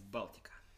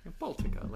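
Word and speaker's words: Baltika: It's Baltica. – Baltica. – Yeah. – Baltica, like the Baltic Sea? – Yep, exactly.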